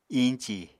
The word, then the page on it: iñci: apatani